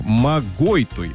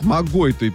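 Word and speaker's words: Могойтуй: Ну а Ольга Борисовна из Иркутска поздравляет с днем рождения Наталью Сергеевну Гончарову, собственно, из Иркутска, и Марию Федотову из поселка Магайтуй. Ударение на второй слог. Магойтуй. Вот, извините, вот так вот. Магойтуй.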